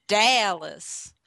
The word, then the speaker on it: Dallas: And then, of course, there's Dallas. You put the emphasis on that first syllable.